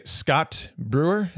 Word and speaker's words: Scott Brewer: I have with me today Scott Brewer.